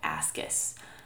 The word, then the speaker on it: ASCUS: It's called ascus. It's spelled A-S-C-U-S, which stands for atypical squamous cells of undetermined significance.